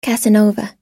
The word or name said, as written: casanova